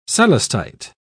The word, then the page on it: celestite: British English